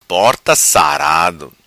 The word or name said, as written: Sarado Porta